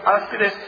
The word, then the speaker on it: áspides: Así se menciona, así se pronuncia, áspides.